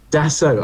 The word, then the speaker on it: Dassault: Dassault.